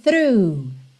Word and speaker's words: through: Lembrando a pronúncia dessa palavra, T-H-R-O-U-G-H. O G-H do final você ignora, não vai ter som nenhum.